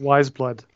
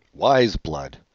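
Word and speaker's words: Weyes Blood: Every Day, Weyous Blood, or Weyous Blood, I don't know how you say it. – Oh, I think it's Wise Blood. – Wise Blood.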